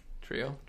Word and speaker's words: Trejo: Trio? You don't pronounce the J. It's cool like that.